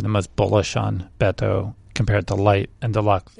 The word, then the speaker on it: Beto: By the way, I listened to reader feedback that said I was pronouncing it extremely white and it's saying Beto. So I'm taking the critique and pronouncing it to Beto, which I hope is better.